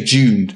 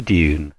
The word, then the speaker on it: Dune: Do you say Dune or do you say Dune, Andy? – I go for Dune. – Dune. I'm more of a Dune.